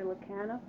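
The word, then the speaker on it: Lekanoff: My name is Mary Lacana.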